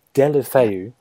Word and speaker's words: Deulofeu: Is Gerard Deulofeu, is that how you say his name? – Deulofeu. Watch a lot of the league, dear.